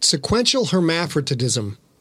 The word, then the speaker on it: sequential hermaphroditism: Okay, so the uh, sequen- sequential hermaphroditism did I pronounce that right? Yes. – Yep.